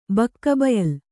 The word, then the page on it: ಬಕ್ಕಬಯಲು: bakka bayalu